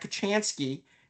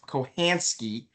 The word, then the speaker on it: Kachansky: he will actually say it's supposed to be pronounced Kohansky, which I don't even understand. The C is supposed to be silent.